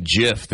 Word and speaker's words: GIF: How do you guys pronounce G-I-F-F? – Yeah, I know. This one goes GIF or JIF, right? – Yeah. – I say GIF. – It's supposed to be JIF.